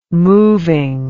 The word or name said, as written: moving